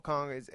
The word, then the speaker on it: Okung: I mean, Russell Okung, he's a guy that's probably going to be – did I say it right? Okung? – Okun. Yes.